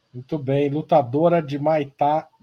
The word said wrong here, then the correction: Muay Thai: Desculpa, falei errado. Muay Thai, Jade Beatriz.